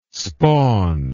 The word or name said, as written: spawn